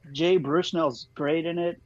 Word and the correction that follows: Baruchel: Jay Bruchnell? Am I saying it right? – I think it's Baruchel. Yeah, he's Canadian.